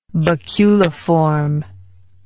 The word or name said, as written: baculiform